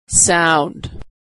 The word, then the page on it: sound: aw